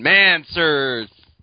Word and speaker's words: Mancers: Yeah, it's like answers for men. Mancers.